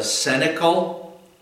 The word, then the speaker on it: cenacle: My dear fellow Christians who are following in the footsteps of our Savior, it's called the cenacle. That's spelled C-E-N-A-C-L-E, cenacle.